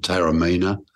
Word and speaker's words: Taormina: is it Teorama, I think you pronounce it, Taormina, which I was lucky enough to visit a few years ago.